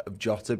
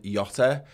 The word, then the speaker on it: Jota: To be fair, I don't know if anyone is on to Micah Richards' his pronunciation of Jota being Yota.